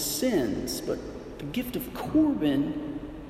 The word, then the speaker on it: Korban: These types of sacrifices are called Korban. You can look this up, K-O-R-B-A-N. In Mark 7, they spell it C-O-R-B-A-N.